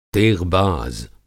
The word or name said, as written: तेग़बाज़